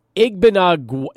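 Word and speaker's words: Igbinoghene: I see Igbinogami. – Igbinogami? – Yes, that's right. – Agony. Gni. All right. Igbinogami.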